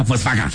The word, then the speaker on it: Volkswagen: Volkswagen, que se pronuncia Volkswagen.